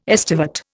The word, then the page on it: estivate: Indian